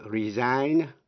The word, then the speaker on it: resign: Vậy ta biết động từ resign cũng theo lối phát âm như động từ sign, nghĩa là âm G cấm khi là động từ resign. Nhưng khi thành danh từ thì G có phát âm resignation.